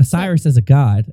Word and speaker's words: Osiris: Osiris.